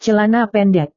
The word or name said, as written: celana pendek